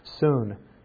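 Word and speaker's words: Soon (σύν): Soon is how it's pronounced.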